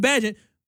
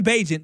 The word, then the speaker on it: Bagent: So Tyson Bagent, I'll say it right. Tyson Bagent,